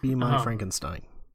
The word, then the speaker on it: Frankenstein: Frankenstein's monster. – Kind of. It's pronounced uh, Frankenstein, by the way.